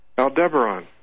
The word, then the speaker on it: Aldebaran: Aldebaran. – Aldebaran. Okay, so the emphasis on the D-E.